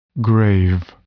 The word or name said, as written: grave